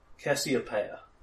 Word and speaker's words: Cassiopeia: Cassiopeia, that's how it's pronounced.